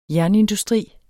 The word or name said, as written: jernindustri